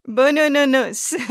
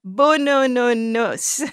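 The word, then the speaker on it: boonoonoonous: Bunununu's Hair is a children's book by poet and author Olive Sr. Bunununus. – Who very patiently explained how to pronounce the title. – Bunununus. You break it down into bunununus. And then you say it fast.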